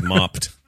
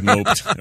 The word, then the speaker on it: moped: Age 43 is riding his moped, or as I would have pronounced it had I not looked at it earlier, his mopped. – His moped. – Or moped is even better.